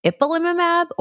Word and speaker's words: ipilimumab: The good thing is that the CTLA-4 inhibitors, if you see ipilimumab or tremelimumab, am I saying that right, Anu? – That's absolutely right.